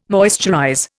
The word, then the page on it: moisturize: Indian